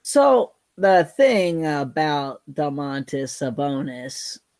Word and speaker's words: Domantas: Say his name one more time. – Dontas Sabonis. – Dontas? – Damantas. Damantas Sabonis. – Domantas. – Damantas Sabonis. – Yeah, someday you'll get it.